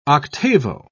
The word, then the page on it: octavo: British English